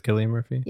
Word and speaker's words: Cillian Murphy: Okay, so it is Cillian Murphy? – Yeah. – All right. It stars Cillian Murphy as Robert Capa, Rose Byrne as Cassie.